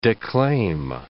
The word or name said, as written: declaim